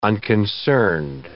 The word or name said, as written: unconcerned